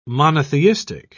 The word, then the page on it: monotheistic: British English